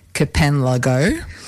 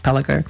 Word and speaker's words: kpalango: So, yeah, so it's spelled actually K-P-A-L-A-N-G-O, which you might think sounds like Kapanlago, right? – It's like Pialajo down in Canberra. – But being a Ghanaian word in the language of Ga, which is the language it's in, it's pronounced Pelago.